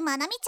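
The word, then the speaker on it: 愛海: 学園でも有名な美少女でアイドル活動もしているまなみちゃん。その正体は、なんとも愛あふれすぎる女の子だったようです。愛の海と書いてまなみと読む。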